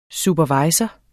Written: superviser

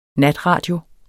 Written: natradio

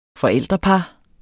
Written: forældrepar

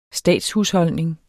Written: statshusholdning

